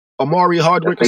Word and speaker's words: Omari Hardwick: That nigga Omar Harrick. Is that how you say it? Harrick? – Omari Hardwick or some shit like that? – Yeah.